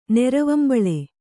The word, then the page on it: ನೆರವಂಬಳೆ: neravambaḷe